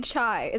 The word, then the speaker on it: Chi: I think it would probably be chai. Is it C-H-I? – Yeah. – I think it'd probably be like lambda chai something.